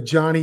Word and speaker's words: Johnnie: Go to Johnny U. It's spelled right there, J-O-H-N-N-I-E.